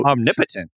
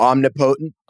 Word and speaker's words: omnipotent: The wrong emphasis on the wrong syllable. – Yeah. – It's tough. English is a stupid language. – Omnipotent, so you just say patent. Instead of potent, it's patent. – Yeah.